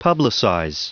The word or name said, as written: publicize